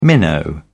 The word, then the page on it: minnow: British English